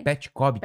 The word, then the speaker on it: Petkovic: O Petkovic, na verdade, se pronuncia Petkovic. – Ah, é? Né? Petkovic.